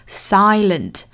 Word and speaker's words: silent: 那有一个双元音哈，注意一定要发音到位，就是 find，包括第一句的这个 silent，不要读成 sigh，应该是 I，口型要张大， silent， find May you find some comfort here.